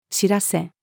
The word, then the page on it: 知らせ: female